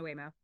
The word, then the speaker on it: Waymo: Waymo.